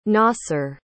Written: nasser

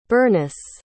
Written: Berniss

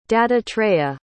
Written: dattatreya

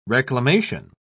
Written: reclamation

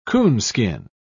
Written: coonskin

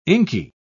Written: inky

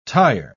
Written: tyre

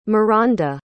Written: mironda

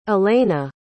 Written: elayna

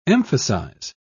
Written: emphasize

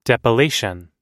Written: depilation

